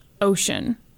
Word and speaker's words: Ossian: and I replaced Ossian, which is how it's like spelled and how I would how I want to pronounce it, and now it just says ocean all over this thing.